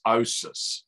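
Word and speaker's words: OSAS: Yeah, and that's again, that's what the once saved, always saved, we compress it into an acronym which we pronounce as OSIS.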